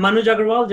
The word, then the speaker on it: Manuj Aggarwal: I'm Manuj Aggarwal.